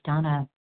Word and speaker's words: Vana: This is Donna B., recovered compulsive overeater and bulimic, calling from Denver. I do spell my name V-A-N-A, even though it's pronounced Donna.